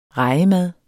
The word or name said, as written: REJEMADDER